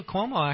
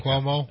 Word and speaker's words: Cuomo: Cuomo, actually, in New York, he was out there. I still can't say his name right. I know. Give me Cuomo. – It's Cuomo. You're right.